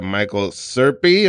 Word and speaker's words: Michael Serpe: Wearing a reading rainbow T-shirt, ladies and gentlemen, we got Michael Serpe. Am I saying it right? – You are, yeah. – All right, Michael Serpe is in the house.